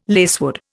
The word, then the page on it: lacewood: Indian